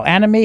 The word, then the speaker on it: anime: Anime, how do you say that? – Anime.